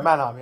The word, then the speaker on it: Mannheim: And Mannheim. – Okay, Mannheim, yeah.